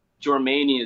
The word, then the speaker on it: Jormani: Like, Jormani is definitely one of the ones that people for sure like to say my name.